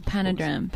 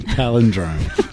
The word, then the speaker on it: palindrome: Is it panadram. Panagram. – Panadram. – Palindrome.